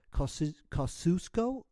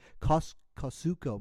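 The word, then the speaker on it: Kosciusko: This cat, of course, is originally from Mississippi, it's a really hard word to pronounce. Kosciuszko, Mississippi. That's K-O-S-C-I-U-S-K-O. That's K-O-S-C-I-U-S-K-O. That's a really tough one. Okay. Kosciuszko, Mississippi. I think that's how you say it.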